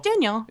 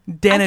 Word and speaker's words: Danijel: That is not Danielle. Or Daniel. – I said Daniel. – It's not Daniel. That's Danijel. – So what? – Danijel.